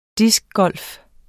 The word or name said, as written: DISC